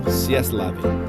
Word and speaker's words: C'est la vie: C'est la vie is the correct pronunciation.